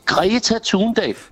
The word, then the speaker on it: Thunberg: Greta Thunberg.